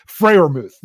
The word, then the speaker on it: Freiermuth: I like Zach Gentry and Pat Fairmouth. – I, I, I, butchered his name, Freiermuth. – There, there, I got it right.